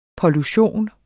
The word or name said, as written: pollution